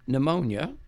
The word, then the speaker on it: pneumonia: And later that year, he was treated for pneumonia. – I hate that word. – I know. It's spelled weird. – How did you pronounce it? – Why is there even a P in there? – And later.